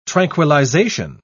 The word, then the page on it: tranquillization: British English